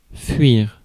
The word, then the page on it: fuir: France; /fɥiʁ/